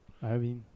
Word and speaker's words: Iovine: Jimmy Loving? Levine? – Iovine or however the fuck you pronounce it. Oh, that's an Iovine. – Yeah. Iovine.